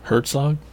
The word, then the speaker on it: Herzog: Herzog, is that how you say it? – Warner Herzog? – Yeah. – Man, he said Zertog. All sorts of... Like, wasn't that a Superman alien or something? – The T is silent, I forgot. – What? – It starts with an H, dude.